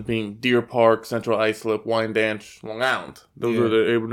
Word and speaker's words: Wyandanch: Spelled WYANDANCH. That's how it's spelled, but it's pronounced Wine Dance.